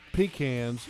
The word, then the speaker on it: pecans: If Shad Khan were wanting to say the proper pronunciation, he would say that it's pecans, right? – Yes. – But I just want to let Mr. Shadrick know okay. It's pecan.